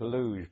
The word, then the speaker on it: Lewes: That's L-E-W-E-S, pronounced Luge by the local people.